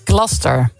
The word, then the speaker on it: Gloucester: De Engelse plaatsnaam Gloucester spreek je uit als... – Gloucester. – Gloucester.